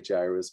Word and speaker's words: Jairus: One of the rulers of the synagogue named Jairus, but it can be pronounced a number of different ways. I usually say Jairus because the A and the I in Greek, it's pronounced that way.